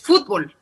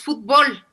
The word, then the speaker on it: fútbol: Y en este caso termina con L, por lo tanto en ese sí se acentúa, sí es fútbol, ¿no? No sé, como los argentinos, el fútbol, ¿no? – Okay. – A ver, pero nosotros en México decimos fútbol, para nosotros la vocal que pronunciamos con más fuerza es la O y por lo tanto tenemos una palabra aguda y recuerden que las palabras agudas son las que tienen la carga tónica en la última sílaba.